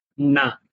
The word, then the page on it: n: Sanskrit